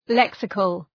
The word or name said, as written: lexical